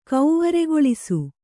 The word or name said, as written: ಕೌವರೆಗೊಳಿಸು